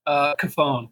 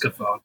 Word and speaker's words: Cofone: You got the like actual Italian pronunciation right. So here in the US, it's just Cofone.